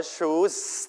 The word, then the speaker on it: choose: choose chosen ตัวนี้ไม่ออกเสียงว่า choose ต้องลงท้ายด้วยเสียง s นะอย่าออกเสียงว่าชุดที่เป็นแปลว่ายิงคนละตัวนะครับอันนี้ choose แปลว่าเลือก